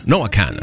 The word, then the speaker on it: Noah Kahan: Noah Kahan, así se escribe, K-A-H-A-N, pero se Noah Kahan, así es que se pronuncia.